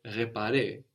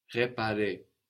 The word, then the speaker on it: réparé: réparé, is written with a little accent over it, a little dash going up from the E, which lets you know to pronounce a stronger E, réservé, réparé.